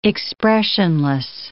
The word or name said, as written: expressionless